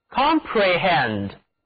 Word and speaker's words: comprehend: 这个词中间 PRE 这个部分里含有的字母 E 读作短 E，而 HEND 这部分中的字母 E 读作 E，整个单词的口型都比较扁平。请跟我读 Comprehend， Comprehend。